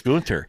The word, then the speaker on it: Gunter: Did I say that right this time, Leanne? Gunter? – Yep. – Yeah. Okay. Okay. – Gunter. – Gunter, Gunter, it's Gunter.